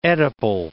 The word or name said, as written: oedipal